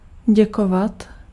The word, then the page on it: remercier: /ʁə.mɛʁ.sje/; France (Paris)